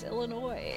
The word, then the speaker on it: Illinois: You don't pronounce the S. It's Illinois.